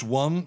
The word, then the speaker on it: womb: Late in time, behold him come, offspring of a virgin's womb. So there's kind of a paradox there. She was a virgin, yet he was born. – Right. – Which pronounced wum so that it rhymes.